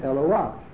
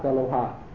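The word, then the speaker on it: Eloa (אלוה): So the correct pronunciation of the Hey with a Patach when it appears at the end of the word is Ah, not Ha, like Koach. When you have a Ches with a Patach at the end of the word, so you don't say Kocha, you say Koach. So here also the correct pronunciation is Eloa, and not Eloha.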